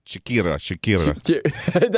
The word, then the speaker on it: Shakira: How do you pronounce Shakira? – Shakira. – There you go.